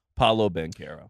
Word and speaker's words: Paolo Banchero: Paolo Banchero. – So the fact that we finally had the full culmination moment of him saying his own name, Paolo Banchero.